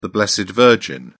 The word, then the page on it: blessed virgin: British English